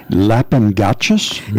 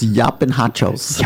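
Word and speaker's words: llapingachos: And one which I starred because I like saying it, lapingachos? – It's yapinhachos. – Yeah. But it's spelled L-L-A-P-I-N.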